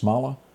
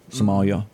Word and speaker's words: Somalia: Sad news is we go to, and I believe you pronounce this, Smala. Is that how you? – Somalia. – Somalia.